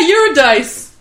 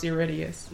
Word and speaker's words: Eurydice: Because everyone's like, yeah, Eurydice. It's like, no, it's Eurydice.